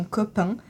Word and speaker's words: COP'1: Est-ce que déjà tu peux te présenter et nous présenter l'association Copain, qui s'écrit COP'1, et ton rôle au sein de l'association ? – Eh bien, je suis Marine, j'ai 24 ans.